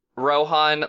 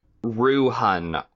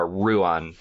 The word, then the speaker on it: Rohan: So is her twin brother, and it's spelled Rohan like the Riders of Rohan from Lord of the Rings, but it's not pronounced that way. Ruhun, R-O-O-H-U-N, is how he pronounces it back to them. So it's more of a Ruan than a Rohan.